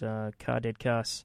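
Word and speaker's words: Kadeadkass: I kicked it off with pretty much my favorite thing in the entire world right now, which is Ka Dead Kass. Yes, that's how you pronounce that. K-A-D-E-A-D, as in dead. K-A-S.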